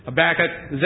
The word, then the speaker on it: Habakkuk: Isaiah, Jeremiah, Ezekiel, Daniel, Hosea, Joel, Amos, Obadiah, we'll skip Jonah, Micah, Nahum, Habakkuk, that's the correct way to pronounce it, Habakkuk, Zephaniah, Haggai, Zechariah, Malachi, and Revelation.